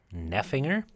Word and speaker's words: Neffinger: Neffinger? – Yeah. – How do you spell that? – N-E-F and then finger. – Oh. So kind of like it sounds.